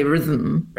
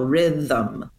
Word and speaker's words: rhythm: But yeah, that THM, it's a two-syllable word. There's no doubt about it. We don't say rhythm, right? Like there is a second vowel, rhythm, 1, 2. And the thum syllable has a schwa vowel.